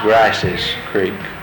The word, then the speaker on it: Grice's Creek: Well, I've seen it spelled two different ways, but it's always been pronounced Grice's Creek with an R sound.